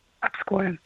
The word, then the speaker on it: Обское: И вы все верно сказали, у нас есть Обское море, Обское, Обское. Все говорят по-разному, но правильно говорить Обское.